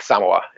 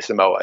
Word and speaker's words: Samoa: he always pronounces like Samoa in this. He does not say Samoa. He pronounces it sort of the emphasis on the first syllable is the kind of more authentic way to pronounce it, I believe.